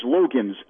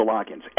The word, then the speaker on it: Loggins: Because the argument sounds very hollow when you refer to him as Logans as opposed to Loggins.